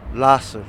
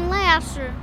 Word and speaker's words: Lahser: Lasher, not Lasser? – No, Lasher. – Even though it's spelled L-A-H-S-E-R? – Yes. – Lasser. – Oh, really? – Yeah. – So it's not Lasher. – No, it's Lasser. – My nana live on this street. – How do you say it? – Lasser. – Some people pronounce it Lasher. – What way is the right way? – Lasser.